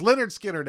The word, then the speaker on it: Lynyrd Skynyrd: Oh, I'm pronouncing that phonetically. I'm sorry. It's Lynyrd Skynyrd, everybody.